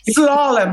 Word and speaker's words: slalom: So you're in a band called Slalom D. I can't say it properly. – Slalom, like the ski. – Oh, slalom. Right.